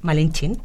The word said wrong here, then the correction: Malintzin: ¿no? – Malintzin. – Malintzin, pronunciado.